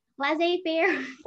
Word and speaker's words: laissez-faire: The laissez-faire governance. Yeah. – Yeah. – Yeah. I'm probably pronouncing that wrong with a horrible accent, but it's okay. – Laissez-faire.